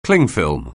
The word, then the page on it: clingfilm: anglais